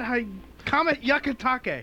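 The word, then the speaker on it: Hyakutake: Comet Hayakutake. I didn't say that right again. – Just drop the H. – Try it again. Comet Hayakutake. – He said drop the H. Don't say the H.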